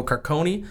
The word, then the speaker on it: Carcone: the Ottawa Senators get Con- Connor Brown, Nikita Zaitsev, and Michael Carcone, I believe is how you pronounce yep.